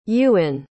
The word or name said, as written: Yuen